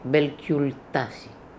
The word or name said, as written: Belcultassi